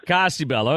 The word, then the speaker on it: Cosi Bella: It's so beautiful, of course, but Cosi Bella, is that how you pronounce it? – Cosi Bella. – Okay.